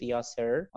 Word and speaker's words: Yasser: The name of the page is English with Yasser. Okay, English with Yasser, and Yasser is spelled Y-A-S-S-E-R.